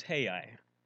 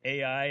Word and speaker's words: Ai: And enter into the story of, and incidentally, the pronunciation Hebrew is Hai. It'd be Hai. So we've kind of anglicized a little bit. Most people say Ai, and I've even heard people call it I.